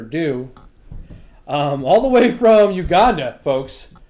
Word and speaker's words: Uganda: Uganda, that's how I say it. That's how the locals say it.